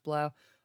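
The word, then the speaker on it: 3LAU: Next up is Blau, spelled 3 Lau, but it's pronounced Blau.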